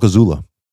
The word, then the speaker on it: Azula: Let's talk Azula.